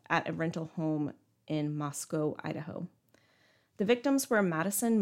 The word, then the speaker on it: Moscow: I just learned that it's pronounced Moscow. Oh. Like rhymes with Costco.